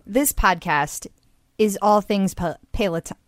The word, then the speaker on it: Peloton: So, okay, we know, no need to write in, we know that it's pronounced Peloton.